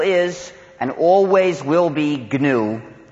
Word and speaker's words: GNU: In any case, when it's the name of our operating system, please pronounce a hard G. Pronounce it GNU. If you speak of the new operating system, you'll get people very confused.